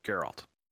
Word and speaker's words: Geralt: You're putting the emphasis on the wrong syllables. – Ah, okay. So yeah. Do it again, but slow. – Geralt. – Geralt. – So Geralt. – Alt. Geralt. – Yep, Geralt.